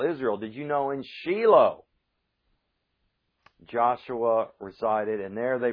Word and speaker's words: Shiloh: And we have that example here with the name of Messiah called Shiloh. Now, notice I didn't say Shiloh, because the correct Hebrew pronunciation is Shiloh.